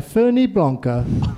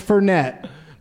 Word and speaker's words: Fernet: And he goes, "As I sit there drinking my Ferni Blanca," and it's Fernet is how it's pronounced. I found out later. He just made up a new way to say it.